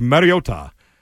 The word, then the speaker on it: Mariota: I thought the Broncos were going to wax that team with Marcus Mariota, which I guess is how you pronounce his name. We've been saying it wrong all this time, and now they're saying it right last night.